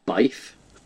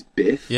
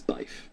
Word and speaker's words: Byf: Yeah, but it's spelled BYF. So every the first time I saw it, I thought it was My Name Is Biff, Yeah. – but it's Bife.